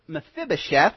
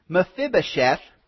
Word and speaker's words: Mephibosheth: But then in the broader context, Mephibosheth, I knew I'd mispronounce it at least once, Mephibosheth, that story comes in 2 Samuel 9.